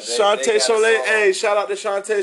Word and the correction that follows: Chantel Soleil: No, I'm saying it wrong. Chantel Soleil, excuse me.